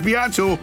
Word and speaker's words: Beato: You didn't say it right, It's not Beato, it's Beato.